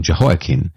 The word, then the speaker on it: Jehoiakim: Now, there was Jehoiakim with an M at the end, and Jehoiakim with an N at the end. I've also heard his name pronounced Jehoiachin, with a kind of soft ch. But Jehoiakim is how I committed to saying it when we were reading 2 Kings and 2 Chronicles.